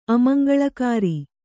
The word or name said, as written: ಅಮಂಗಳಕಾರಿ